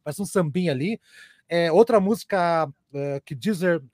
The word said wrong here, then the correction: Geezer: uh, ou Geezer, né, a patrulha da, da pronúncia aí, Geezer uh,